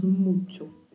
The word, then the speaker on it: mucho: Temos que ter cuidado aqui com o CH, na letra C e na letra H, que é pronunciado com o som que nós usamos para a palavra tchau. Então falamos mucho.